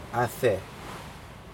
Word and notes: ade: Aneityum

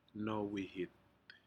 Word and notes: nowihit: Aneityum

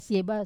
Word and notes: seba: wamesa

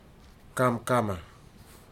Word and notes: kamkama: futuna aniwa

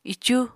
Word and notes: icu: apatani

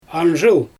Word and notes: anzhyl: Xyzyl

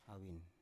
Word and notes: avin: Biak

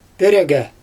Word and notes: pIrEgE: Xyzyl

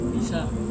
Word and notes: bisa: Serewen

